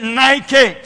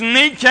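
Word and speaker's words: Nike (νίκη): and if I spelled it out today, you would pronounce it Nike. But really the Greek pronunciation is Nike,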